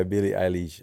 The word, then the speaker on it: Billie Eilish: Billie Eilish, I would love to work with her. I said it right? – Yeah. – I said it right this time. Yeah, Billie Eilish.